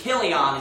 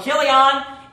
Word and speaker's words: Chilion: Most of the words that have a CH that begin with a CH, I mean 95% of the time, it's going to be a cuss sound, like, you know, like Chaldean. So this is Chilion is actually how you pronounce that.